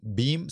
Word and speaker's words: Beam: BIM se escreve B-E-A-M.